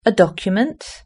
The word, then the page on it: document: British English